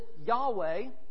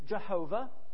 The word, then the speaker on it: Yahweh: We pronounce it Yahweh, or in Latin, Jehovah.